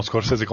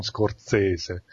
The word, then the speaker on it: Scorsese: Ricordiamo Scorsese con due S, non Scorsese, non so, molti sbagliano. – Sì. Ma quando, cioè lui stesso dice di chiamarlo Scorsese, che è americano, però...